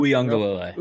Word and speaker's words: Uiagalelei: Yeah, I mean, I think it all starts with DJ Uiagalelei. I don't know if I pronounced that. – Uiagalelei.